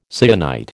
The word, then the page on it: syenite: American English